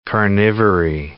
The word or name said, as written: carnivory